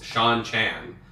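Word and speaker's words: Seanchan: A total aside, but Sanshin equals Sean Chan. Rhymes with Lon Ann.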